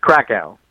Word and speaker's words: Krakow: It's cool, Krakow.